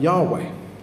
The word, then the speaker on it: Yahweh: Now, in, in the original text, there were no vowels included, but the scholar's kind of best estimation is that it's pronounced something like Yahweh.